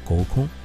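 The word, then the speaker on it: 合コン: 在日本的话，还有一个让我印象特别深刻的活动，就是联谊，日语里读作沟空。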